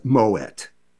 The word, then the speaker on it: Moët: The one that people always worry about is M-O-E-T. It is Moet. It's of Dutch derivation. And the French would say Moët et Chandon. They say it very quickly. So it's Moet, it's Perrier Jouet.